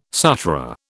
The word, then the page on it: Sutra: British English